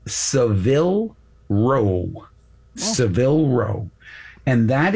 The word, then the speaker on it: Savile Row: It's not Savile Row, it's Savile Row. Seville is a city in Spain. The place where you can get really good, expensive suits, best suits in the world, Savile Row. Accent on the first syllable, and it's a letter A.